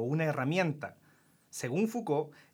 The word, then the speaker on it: Foucault: Foucault.